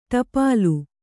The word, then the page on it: ಟೋಪಲಿ: ṭōpali